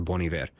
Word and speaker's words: Bon Iver: Y les digo que estoy pronunciando mal porque toda la vida le dije Bon Iver hasta que alguien me dijo, no, eso de hecho significa buen invierno en francés y se debería pronunciar Bon Iver.